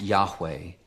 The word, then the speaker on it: Yahweh: Now, in Hebrew, "I am" is pronounced Yahweh.